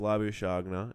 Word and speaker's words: Labuschagne: Yeah, but no, it's Labuschagne is how it's meant to be pronounced.